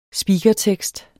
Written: speakertekst

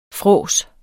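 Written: fråds

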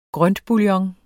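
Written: grøntbouillon

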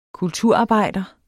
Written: kulturarbejder

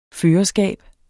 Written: førerskab

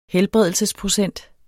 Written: helbredelsesprocent

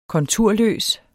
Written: konturløs

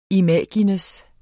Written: imago

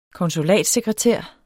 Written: konsulatssekretær

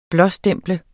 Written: blåstemple

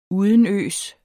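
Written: UDENØS

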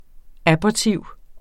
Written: abortiv

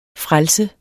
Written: frelse

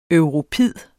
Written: europid

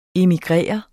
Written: immigrerer

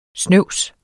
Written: snøvs

